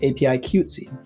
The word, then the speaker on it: APIQWTC: Crystal Jung is a San Francisco-based activist who has co-founded organizations for Asian and Pacific Islanders, including APIQWTC, which is pronounced APIQTSY, OASIS, which is now a part of APIQTSY, and the Red Envelope Giving Circle.